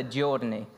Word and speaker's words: giorni: Comes from their words, 40 giorni. Apparently I pronounced that right this morning, according to an Italian.